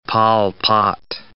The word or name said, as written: Pol Pot